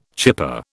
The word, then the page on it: chipper: British English